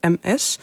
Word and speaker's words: Ms: Maar wat je ook vaak op ziet komen is het overkoepelende Ms. En dat spreek je dan uit met een Z, maar je schrijft het als MS.